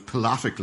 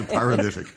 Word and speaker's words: paralytic: But and then and uh, probably the most Irish mispronunciation is when somebody's been out on the lash. And you're talking to them, and they say, "I was pilatic last night," as opposed to paralytic, which I think is a very Irish thing.